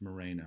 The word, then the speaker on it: Moreno: It's Moreno, I know.